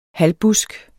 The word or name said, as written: halvbusk